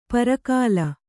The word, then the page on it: ಪರಕಾಲ: Kannada